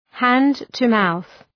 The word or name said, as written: hand-to-mouth